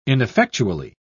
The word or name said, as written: ineffectually